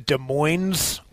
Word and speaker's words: Des Moines: We have there's a Des Moines, Iowa, and then there's a Des Moines, Washington, spelled the same, but you say the S.